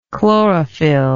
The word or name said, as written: chlorophylloid